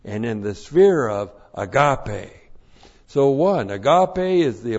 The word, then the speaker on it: agape: And after going through 1 John and studying 1 John, you are clued in when the Apostle John uses agape. A-G-A-P, long E.